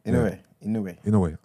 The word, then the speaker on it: Inoue: All right, again, how do you pronounce his name, please? – The boxer, Inoue. – All right. Yeah. Inoue delivered us.